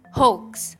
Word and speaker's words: hoax: Fun fact ya, tulisannya kan H O A X tapi bacanya bukan hoax. Kayak gue sering banget denger uh, apa namanya orang-orang tuh ngomong hoax, hoax gitu. Penyebutannya tuh hoax, padahal yang benar adalah hoax. Jadi A-nya nggak usah dibaca di situ. Anggap aja A-nya nggak ada lah.